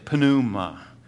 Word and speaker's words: pneuma: And this word Spirit is the Greek word, we would say it pneuma, but when you in Greek you pronounce the P just a little bit. So they would say pneuma.